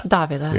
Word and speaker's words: Davila: Davila. Yeah, we were talking about this before, and uh, I was thinking it's Davila, but that's Spanish.